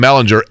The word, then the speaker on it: Ehlinger: Ehlinger, right? Is it Ehlinger or Ehlinger? – Ger. Hard G.